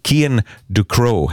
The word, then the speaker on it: Kian Ducrow: där han själv uttalar sitt namn, så nu kan vi säga det med självsäkerhet. Kian Ducrow heter han då, alltså. Så föredrar han själv att man uttalar det.